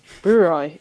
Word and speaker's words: Brouwerij: Brewery, is that how you say it? – Yeah, IJ is I.